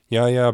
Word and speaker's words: Yahya: Yahya Abdul-Mateen the second.